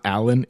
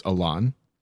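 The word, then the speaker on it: Alain: At the very beginning, it's you know how the guy's name is spelled Alan? It's not Alan, it's Alon.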